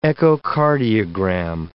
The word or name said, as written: echocardiogram